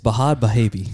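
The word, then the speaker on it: Bhad Bhabie: That is not how you say it. It's Bahad Bahabi.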